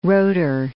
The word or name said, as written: rotor